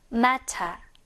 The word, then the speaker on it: matter: 문제라는 뜻인데요. 미국식으로는 matter이지만 영국식으로는 그렇지. matter. 한번 더 matter. 아, 이렇게 발음이 되겠구나.